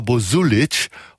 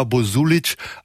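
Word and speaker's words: Bozulich: Davor auch diese Nummer, irgendwie schwarz-bluesig angehaucht, kommt von Carla Bozulic, hoffentlich richtig ausgesprochen, die Endung bei ihr CH, Carla Bozulic aus ihrem Album Boy.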